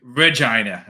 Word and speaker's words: Regina: Make sure Regina.